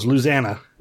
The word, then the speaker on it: Louisiana: Unless you're from Louisiana, then it's, yeah. – That's like all one thing for them. Louisiana. – It's three syllables. Louisiana.